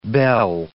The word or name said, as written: belle